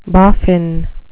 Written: boffin

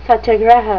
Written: satyagraha

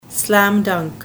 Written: slam dunk

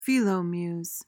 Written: philomuse

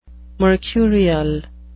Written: mercurial

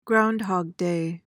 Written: groundhog day

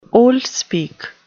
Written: oldspeak

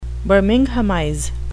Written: birminghamize

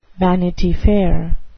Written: vanity fair

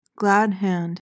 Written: glad hand

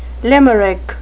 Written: limerick

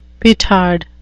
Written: petard

